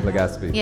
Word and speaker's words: Legazpi: Legaspi.